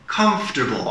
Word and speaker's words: comfortable: So one more time, not comfortable, but comfortable. I see it as three syllables as a native. Comfortable, comfortable.